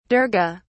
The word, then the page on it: durga: British English